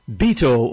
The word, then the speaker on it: Beto: Some people have been funning with his name this week and calling him Beto O'Rourke, I guess because it rhymes with veto.